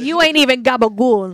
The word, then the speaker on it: gabagool: And that's what gabagool is. That's just how they pronounce it in this very small sect of, like, New Jersey. They call it gabagool.